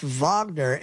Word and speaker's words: Wagner: Yes, and it's pronounced Wagner, and people are calling it Wagner, and I guess that's the way they've been writing it, but I saw the Cyrillic of it.